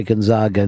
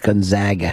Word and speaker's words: Gonzaga: People from the West Coast get pissed at us, me and you, because we say Gonzaga instead of Gonzaga. – Gonzaga. – Oh, bullshit. – I don't like that pronunciation. It sounds too, you know what.